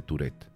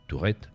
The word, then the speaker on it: Tourette: Y bueno, libro al que he querido volver para abordar un tema importante para Sachs como es el síndrome de, y lo voy a decir ya tal cual lo leemos en, español, en castellano, de Tourette, ¿vale? sin, darle esa pronunciación francesa. Tourette. Tourette sería en francés, que quiere, algo, que quiere decir algo así como pequeña torre.